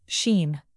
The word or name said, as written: sheen